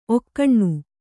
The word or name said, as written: ಒಗುನಿ